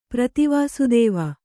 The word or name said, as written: ಪ್ರತಿವಾಸುದೇವ